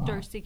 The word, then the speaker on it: Darcie: Darcy Katie. – And can you spell that for me? – D-A-R-C-I-E. Last name C-A-D-Y.